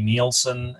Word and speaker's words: Nielson: Nielson, and my last name is spelled Nielson.